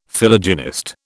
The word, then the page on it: Philogynist: British English